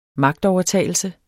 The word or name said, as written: magtovertagelse